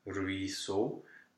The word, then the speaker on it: ruisseau: E-A-U is a standard spelling. It's just a closed O, ruisseau. And every time you see an E-A-U, you will have to go automatically to a closed O.